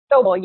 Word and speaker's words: Sobel: Sobel, yep.